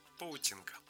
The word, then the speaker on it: Полтинка: Ее в народе кратко зовут «Полтинка», а произносят как «Паутинка».